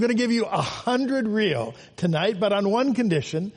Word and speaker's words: riel: The Cambodian money is called the riel. It's spelled R-I-E-L. But you can really call it, it's real money.